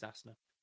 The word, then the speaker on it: Asner: The other thing I was going to mention was the sad passing of Ed Asner, is it? Is that how you pronounce his surname? Or Asner? Eisner? No, it is Asner, who passed away this week.